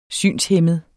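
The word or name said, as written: SYNSHÆMMEDE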